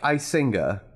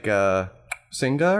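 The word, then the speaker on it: Icinga: Now, it's spelled like Isinga, and that's how I usually pronounce it, but the proper pronunciation is like Singa or something. You know, it's one of those African languages that I can't recall off the top of my head right now, where they use the click consonants. So they use that in the name, but nobody can pronounce that, so just call it Isinga and you're fine.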